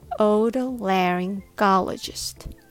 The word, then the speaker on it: otolaryngologist: Otolaryngologist. Otolaryngologist. A tip for pronunciation. Try following this rhythm pattern. Da-da-da-da-da-da-da. Da-da-da-da-da-da-da. Otolaryngologist. Otolaryngologist. And remember, the main stress is on gall.